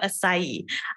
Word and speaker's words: acai: I would like to ask you about, I know, I would like to ask you about acai.